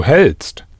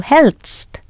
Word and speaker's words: hält: How would you pronounce the du form, spelt H-A umlaut L-T-S-T? – Du hältst. – Du hältst.